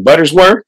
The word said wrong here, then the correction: Butterworth: Mrs. Butterworth.